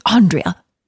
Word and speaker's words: Andrea: Well, I liked it before that when Andrea walks in on Munson and they say her name wrong and she's like, it's Andrea.